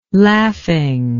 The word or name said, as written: laughing